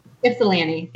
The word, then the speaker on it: Ypsilanti: Ypsilanti.